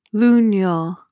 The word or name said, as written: lunule